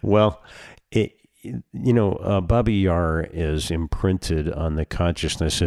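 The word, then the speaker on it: Babi Yar: So Baba Yar is the Russian name, and Babin with an N is the Ukrainian pronunciation.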